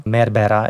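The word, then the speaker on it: Meyerbeer: Asi nejhorší role, kterou všichni nenáviděli, ale všichni museli zpívat, je prorok Giacoma Meyerbeera. Já to vyslovuji francouzsky, ale možná byste raději chtěli německou výslovnost Meyerbeera.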